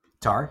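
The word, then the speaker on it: Tár: Tar, am I saying that right? – Tar, yep. – Tar. There's an accent on the A, so I didn't know if it was like... Tar. – Yes, it is. It is Tar.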